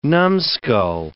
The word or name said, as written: numbskull